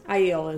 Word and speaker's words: Aiel: Aiel is... – Aiel is how they're doing it in the show. – All right, let's try to stay consistent with that then. He says, Matt, he's an Aiel.